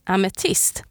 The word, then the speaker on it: ametist: And in Swedish, amethyst is called amethyst. Pretty similar sounding, eh? And it's spelled A-M-E-T-I-S-T.